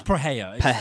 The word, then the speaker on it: Pereira: It's Pereira. That's the Portuguese pronunciation.